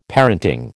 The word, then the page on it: parenting: American English